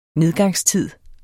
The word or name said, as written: nedgangstid